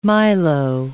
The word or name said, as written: milo